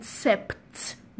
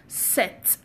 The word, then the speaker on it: sept: Many of you may see it and call it sept because there is P in the letter P there. No, it is pronounced set, as in on your mark, get set, go.